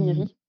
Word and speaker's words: Oniri: Oniri. – Oniri Ok. – Oniri. – Oniri. Ça se prononce comme le, le, dieu du. – Ok, Oniri. Moi, je, ouais, je le prononçais un peu littéralement, donc merci.